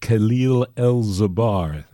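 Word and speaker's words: Kahil El'Zabar: Well, I want to go over to a track from Chicago percussionist Khalil El-Zabar. That's spelled K-A-H-I-L, Khalil, and then E-L apostrophe Z-A-B-A-R, Khalil El-Zabar.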